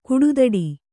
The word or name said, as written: ಕುಡುದಡಿ